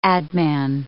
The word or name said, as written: adman